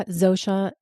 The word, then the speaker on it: Zosia: So, yeah, Zosia, and it's Z-O-S-I-A.